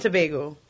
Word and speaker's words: Tobago: is that the right pronunciation? – Trinidad and Tobago. – Tobago.